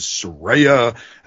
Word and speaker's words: Saraya: But if somebody was in the it's for this word and the actual pronunciation is Saraya and she's like, ladies and gentlemen, have you heard this woman speak? Yeah. She barely speaks English. She's from England. – Saraya.